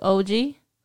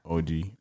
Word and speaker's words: Oggi: Which, shout out to OG, Trattoria. I think I said that right. – Yeah, OG. – Yes. – O-G-G-I.